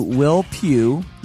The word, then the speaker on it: Pugh: I am your host, Shane Told, and today I talk to Will Pugh, yes, that's how you say his name, of Kartell.